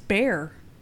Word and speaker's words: Bexar: Bexar County? No, it's Bear, clearly.